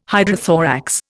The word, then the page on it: hydrothorax: Indian